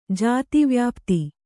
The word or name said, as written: ಜಾತಿವ್ಯಾಪ್ತಿ